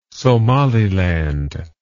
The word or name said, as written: Somaliland